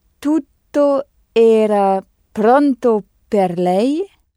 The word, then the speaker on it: tutto: Tu se dit tutto. T-U-D-T-O. Tutto. Avec deux T qu'il faut prononcer bien distinctement.